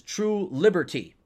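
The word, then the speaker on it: Trulbert: But it's supposed to be pronounced phonetically, which is True Liberty.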